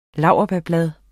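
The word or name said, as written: LAURBÆRBLADE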